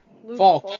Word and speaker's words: Falk: Is it Flank? Falk? What is his name? – Falk. – Luke Falk. That's a weird way to pronounce Falk.